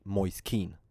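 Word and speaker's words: Moise Kean: Men nu när han, i hans presskonferens så frågar man honom Alltså, är det okej okay att typ uttala ditt namn Moise Keen? Och han eh, gav ett liksom, alltså han, han accepterade det, och alltså, fan säger man?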